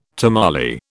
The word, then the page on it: tamale: British English